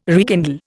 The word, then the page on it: rekindle: Indian